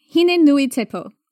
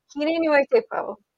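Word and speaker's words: Hine-nui-te-pō: So honestly, I was trying to do with the goddess Huitipilo. – Hine Nuitipo, I believe is how you say it. I hope I didn't screw it up. – Hine Nuitipo.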